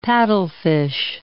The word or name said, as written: paddlefish